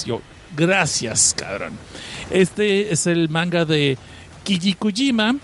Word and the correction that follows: Kichikujima: Perdón, lo dije mal. Kichikujima, es Kichikujima del autor Hokaso no Maizaya.